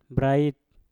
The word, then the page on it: brait: Mpur